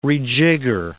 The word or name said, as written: rejigger